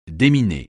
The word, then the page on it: déminer: français